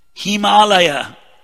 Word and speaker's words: Himalaya: Himalaya. They call it Himalayas because they can't pronounce it properly.